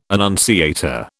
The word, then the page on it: annunciator: British English